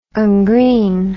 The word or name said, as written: ungreen